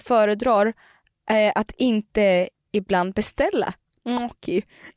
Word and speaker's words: gnocchi: Nej, men här så har vi, jag har tagit fram här, proper pronunciation for gnocchi. – Alltså, gnocchi. Gnocchi. If you want to be Italian.